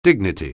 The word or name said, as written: dignity